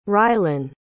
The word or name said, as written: rylan